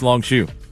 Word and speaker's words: Longshoe: And he's like, no, it's Longshoe. Just the way he pronounced it.